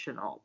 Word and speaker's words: Shenault: Laviska Shenault, is that how you say it? – Shenault, but yeah.